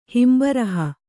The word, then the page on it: ಹಿಂಬರಹ: himbaraha